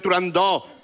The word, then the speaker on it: Turandot: Yo también lo digo mal. Si es que son muchos años diciéndolo mal. – ¿Verdad? – En el mundo exótico en el que vive Turandot, con abierta. – Turandot.